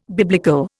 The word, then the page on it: biblical: Indian